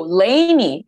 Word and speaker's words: Lainey: Oh, Lainey. It's Lainey. – Is it Lainey? I don't know how to pronounce it. I hate when they write names. And I'm like, I'm sorry. – It's definitely Lainey.